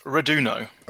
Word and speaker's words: Reduno: It's Reduno. – Okay. – It's an Italian word.